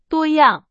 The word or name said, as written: 多样